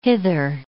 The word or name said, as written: hither